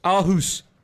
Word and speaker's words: Aarhus: I mean, I don't know how to say it. – Yeah, Aarhus. – Aarhus.